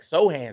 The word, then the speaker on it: Sochan: I was calling him Jeremy Sochan. I'll let you know, honestly, I go onto YouTube and get the pronunciation for the name. So then I'm watching the game, and they was like, Sochan, Sochan, Sochan. I was like, that's not his name.